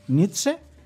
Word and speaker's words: Nietzsche: Ya existe el libro La Gaia Ciencia, el libro de filosofía de La Gaia Ciencia de Friedrich Nietzsche. ¿Lo he dicho bien, Polar? – Sí.